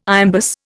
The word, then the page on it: iambus: Indian